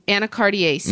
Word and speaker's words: Anacardiaceae: Anacardiaceae. – Anacardiaceae.